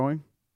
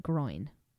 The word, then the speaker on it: groin: Gro- growing. It's definitely groin. Groin. You guys take. Dad says it really bad. He says growing.